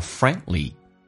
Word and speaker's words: friendly: A 这个发音记住，很多人喜欢发成 friendly 是因为把 A 发成 A 这个发音了，打开口腔一点， A friendly D 跟 L 在一起的时候， D 可以不发音，叫 friendly， friendly， friendly。